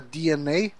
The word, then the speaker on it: DeNA: e, então a Nintendo aí, ela foi atrás da DNA, né? Acho que se pronuncia assim o nome dessa empresa. Se escreve Dena.